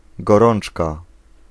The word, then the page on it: temperature: American English